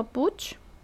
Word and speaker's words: Puig: Si ponen en, em, en Amazon Mario Alonso Puch, bueno, Puch se escribe como P-U-I-G. Es un apellido catalán, ¿no?